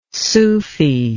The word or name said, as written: sufi